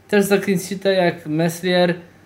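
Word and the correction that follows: Meslier: Melier, tak, bo już w tym tygodniu, jak oglądaliśmy mecz, to się zastanawiałem właśnie, czy się wymawia to jest, czy się nie wymawia? No nieważne. Jest taki nieme chyba.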